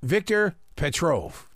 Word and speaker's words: Viktor Petrov: In this book, Putin being Putin, he knows all and enters Viktor Petrov.